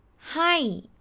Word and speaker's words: ให้: Firstly the word hi is pronounced hi with falling tone Hi together Hi, alright?